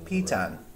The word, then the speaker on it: piton: Piton. That's how you pronounce it.